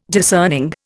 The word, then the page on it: discerning: Indian